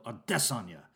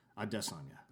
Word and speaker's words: Adesanya: And at a certain point in interviewing and working with Israel Adesanya, Ariel Helwani discovered that the actual way to pronounce Israel Adesanya's name is, in fact, Israel ya. Like he's going to diss on you, you know, like Israel Adesanya. Adesanya.